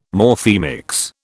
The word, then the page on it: Morphemics: British English